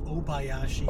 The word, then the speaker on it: Obayashi: virulent racist, Yoshi Obayashi. – It's actually pronounced Yoshi Obayashi. – Obayashi. – Like, oh my gashi.